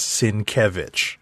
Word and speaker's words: Sienkiewicz: And his Twitter handle is where he puts up a phonetic pronunciation of his name. So that is why I know. Oh, yeah. That is why I am comfortable when I see his name pronouncing it. It is Sienkiewicz.